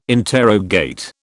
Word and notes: interrogate: British English

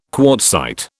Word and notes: quartzite: British English

